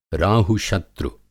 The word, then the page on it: राहुशत्रु: Hindi